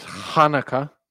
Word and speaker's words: Hanukkah: Then Angelica tells him it's Hanukkah. She says you have to pronounce it with a ha.